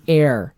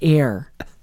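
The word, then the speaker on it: heir: It only listens to the hair of Slytherin. – The air. – The hair? – It's the air. The H is silent.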